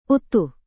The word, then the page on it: utuh: Indonesian